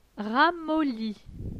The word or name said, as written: ramolli